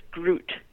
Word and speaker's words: gruit: And this was really referred to as grut, which is spelled G-R-U-I-T.